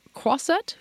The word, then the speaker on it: croisette: So he's going to tell us what he's seen so far at Cannes and what the mood is there on the croisette.